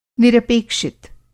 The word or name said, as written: निरपेक्षित